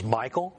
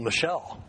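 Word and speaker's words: Michal: which I used to pronounce as Michael, but some woman told me, well, Michelle.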